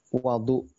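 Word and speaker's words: wadu (وَضوء): Ada dua cara baca yang hanya beda harokat tapi memberikan perubahan makna yang jauh. Yang pertama adalah kata wudhu. Wudhu itu artinya kegiatan berwudhu. Yang kedua wadu artinya adalah air yang digunakan untuk wudhu.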